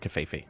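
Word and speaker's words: covfefe: There was actually a Twitter poll that was saying, how do you pronounce it? – What did they come down on? – Most came back with kafefe. – Okay, so we'll go with kafefe.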